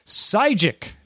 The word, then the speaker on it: Sygic: Sygic, it's spelled S-Y-G-I-C if you want to go look for it, has more than 50 million downloads from the Play Store.